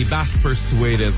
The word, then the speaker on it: Bass Persuaders: Es-tu capable de le prononcer, toi? – Bass Persuaders? – Oui. – C'est ça? – Oui, Bass Persuaders. Moi, si je le prononce comme ça.